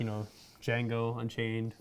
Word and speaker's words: Django: So good that you're saying Django again. I know. I kept saying the Django for the longest time. the D is silent.